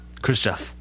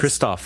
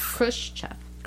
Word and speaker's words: Khrushchev: I really don't want to say his name wrong, but I'm confusing it with Gorbachev, so I'm, like, trying to... – Khrushchev. – Look at the letters. – Christoph.